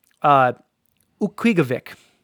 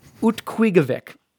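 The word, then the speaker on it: Utqiagvik: Utquigovic. – Oh, you're definitely... – Ukwigvik. – Yeah, no, you're saying it wrong because every time you say it, you add a different letter.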